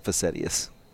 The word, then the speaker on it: facetious: Facetious. – Facetious? – Yeah, but that's how I learned to spell it. – Oh, facetious.